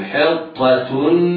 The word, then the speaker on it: حطة: وقولوا حطة نغفر، ما يجيش بعض الناس يفخم الحاء من أجل تفخيم الطاء، أو يفخم التاء المربوطة وصلا من أجل تفخيم الطاء فينطقهما طائي، لا، ننطق حاء مرققة ح، بعدها طاء مفخمة، بعدها تاء مضمومة مرققة منونة هكذا.